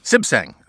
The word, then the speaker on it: Suebsaeng: Oswin Swesbang? How do you do it? – Sibsang. – Sibsang. – Sibsang. It's very Thai.